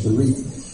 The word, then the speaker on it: berith: Covenant is Strong's 1285 berith, pronounced, and I noticed I left the R out of the pronunciation, berith, from the root word 1262 bara, meaning to eat or to consume.